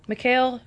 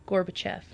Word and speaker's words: Gorbachev: Mikhail? Yeah. – Gorbachev.